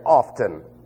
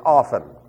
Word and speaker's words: often: The T is silent. You're not showing your education by saying often. You're showing your ignorance. It's often.